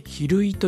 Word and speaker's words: 昼飯: そして岐阜県の大垣市、昼飯と書いて昼井という町があるんだそうですね。